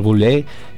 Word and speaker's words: Boulez: También nace en 1912, y disculpen mi terrible francés, nace Pierre Boulez, escritor francés.